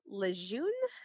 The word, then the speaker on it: Lejeune: There was a case of water contamination, particularly TCE, and this was present at Camp Lejeune, I think I'm pronouncing it correctly.